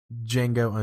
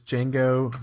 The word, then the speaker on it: Django: You know, you bring up a really good point that anytime Django is announced as a nominee, I really think it should be a requirement that they say the D is silent.